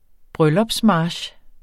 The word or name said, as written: bryllupsmarch